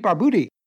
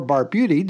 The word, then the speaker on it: Barbuti: He's comedy legend Pete Barbuti, or Barbuti, depending on which pronunciation you like, and we'll ask Pete about his favorite pronunciation.